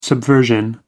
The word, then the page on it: subversion: American English